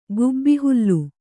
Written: ಗುಬ್ಬಿಹುಲ್ಲು

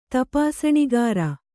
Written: ತಪಾಸಣಿಗಾರ

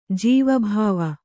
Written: ಜೀವಭಾವಿ

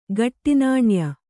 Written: ಖೋಟಾನಾಣ್ಯ

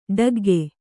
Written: ಠಕ್ಕಿಕ್ಕು

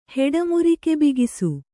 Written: ಹೆಡಮುರಿಕೆಬಿಗಿಸು

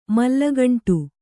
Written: ಮಲ್ಲಗಂಟು

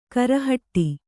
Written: ಗುರಿಹೊಡೆ

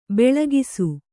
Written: ಭಾಳಾಕ್ಷ

